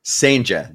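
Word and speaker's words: Cijntje: Sainja, there we go. Yes, I wrote it down actually last night. Gerangelo Sainja is 5'11", and yes, he is a switch pitcher.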